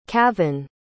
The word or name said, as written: kavin